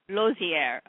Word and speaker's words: Losier: The Michael, it's Losier. – Losier, okay. – Yeah. Well, I-E-R in French is pronounced A.